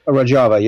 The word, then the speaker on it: Rojava: And the New York Times reported last fall about this autonomous Kurdish region called Rojava. Am I pronouncing that right? – Rojava, yeah.